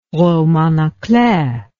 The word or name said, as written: roman a clef